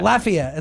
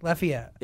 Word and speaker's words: Lafayette: Lafayette. It's like you're laughing, and then an E. Lafayette.